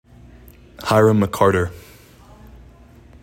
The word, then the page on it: Hyrum McCarter: HIGH rum mih CAR ter